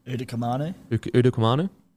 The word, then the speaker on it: Utoikamanu: Look at, like, Stefano Utukamanu. How do you say it again? – Utoikamanu. – Sorry, I forgot that wrong. I mean, I definitely got it wrong.